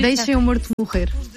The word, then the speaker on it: Deixa-me Morto Morrer: There is a track called Deixa-me Morto Morrer. Mm-hmm. Am I pronouncing that? Am I butchering that pronunciation? – In Portuguese, it's very hard to pronounce Portuguese, but it's almost Portuguese.